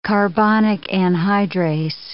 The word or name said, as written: carbonic anhydrase